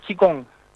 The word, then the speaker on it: qigong: En chino se pronunciaría chikung. – Ah, Chicón.